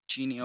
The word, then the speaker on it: Chenny: Your last name would be Chinny. That's how you pronounce it? – Chinny, C-H-E-N-N-Y. – Chinny, okay.